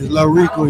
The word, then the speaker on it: Lahricco: It's Lil Rico, you hear me? L-A-H-R-I-C-C-O.